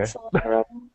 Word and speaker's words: Solatorobo: Was it Solatorobo? – Yeah, I think you said it right. – Okay. – Solatorobo.